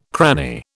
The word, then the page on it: cranny: British English